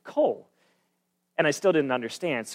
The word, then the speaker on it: Coll: The name was Cole. It's spelled C-O-L-L, and I thought it was just this perfect name.